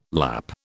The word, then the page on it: Lapp: British English